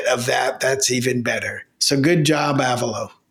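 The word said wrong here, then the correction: Avello: Okay, I hate to be a smartass, but I thought it was a velo, like hello. – It is a velo, rhymes with yellow. – All right. So we got that straight as well. So it's not Avalo, it's a velo.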